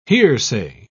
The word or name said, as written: hearsay